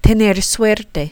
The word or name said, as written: tener suerte